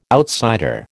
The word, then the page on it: outsider: American English